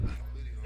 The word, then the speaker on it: Euclideon: I don't know if I'm pronouncing it right, but Euclideon. – That's how I would say it. – Euclideon. Yeah, that's how I'd say it. Euclideon and your clit is on me. – Whoa. Anyway, wow. Euclideon was is from Australia.